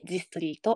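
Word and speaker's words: The Story: えー、ということで、えー、とまず、えー、ジストリーについてなんですけれども、ザ・ストーリーと書いてジストリーと読みます。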